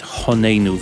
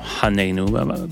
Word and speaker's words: Honeinu: or bad pronunciation, it's Honeinu, but not Haneinu.